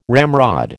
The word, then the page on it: ramrod: American English